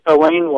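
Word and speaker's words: Alain: When you go to Howard University, or recently on September 13, 2014, when Alan Locke's remains were buried in the Congressional Cemetery, the pronunciation you'll hear there is Elaine Locke. I know it sounds like a woman, and sometimes it gets misspelled, but that's how his name has come to be pronounced.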